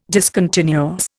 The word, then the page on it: discontinuous: Indian